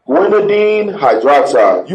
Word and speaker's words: guanidine hydroxide: Guanidine hydroxide.